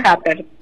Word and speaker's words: Japert: ¿Se pronuncia así su apellido? ¿Mónica? – Happer.